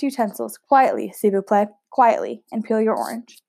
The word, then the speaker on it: s'il vous plaît: Is that how you say it? I'm just learning French now, because that's s'il vous plaît, but it's spelled S apostrophe I L.